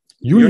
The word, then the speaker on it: Uniper: Äh, sorry, wer? Uniper. Uniper. Ja, genau. Da habe ich nur gelesen, dass der Anteil vom russischen Gas dann noch größer ist. Und auch Uniper hat gesagt, äh, nichts dazu gesagt oder sie haben gesagt, dass die Beteiligung zu russischen Unternehmen äh, nicht abbauen, wie jetzt andere.